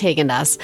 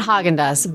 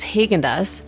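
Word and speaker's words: Häagen-Dazs: And it's pronounced Haagen-Dazs, not Haagen-Dazs, because it has the two dots, which are umlaut above the A. So it's Haagen-Dazs.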